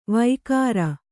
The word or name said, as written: ವೈಕಾರ